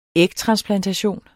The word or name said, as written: ægtransplantation